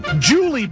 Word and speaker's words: Julie: Julie Buss. – Julie Buss. – Can you spell it for me? – J-U-L-I-E.